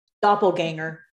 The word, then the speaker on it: doppelganger: Doppelganger.